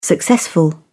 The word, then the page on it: successful: British English